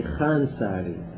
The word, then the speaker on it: الخوانساري: وإذا جاءت الخاء والواو والألف خواء، لا تلفظ الواو وإنما تلفظ الألف خاجة، مثل خنساري، خنساري هي خانساري، اللفظة الصحيحة خانساري، لكن تكتب خاوانساري، صاحب روضات الجنة خانساري، تكتب الخوانساري، هي الخانساري لفظها الصحيح.